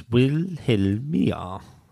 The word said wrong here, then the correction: Wilhelmina: Well, it's got an N before the A. – Yeah. – So I'd pronounce it Wilhelmina.